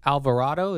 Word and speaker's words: Alvarado: Jose Alvarado. Is that how you say it? – Well said. – Nailed it.